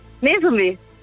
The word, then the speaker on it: Nezumi: Netsumi? – Nezumi. Ja, genau. Nezumi.